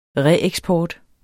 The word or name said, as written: reeksport